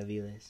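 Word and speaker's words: Avilés: Como Aviles. Is that how you pronounce it? Aviles? – Yeah, Aviles.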